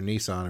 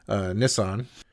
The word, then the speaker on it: Nissan: At one point, the people of Nissan, and that's how you pronounce the car manufacturer Nissan, apparently, Nissan,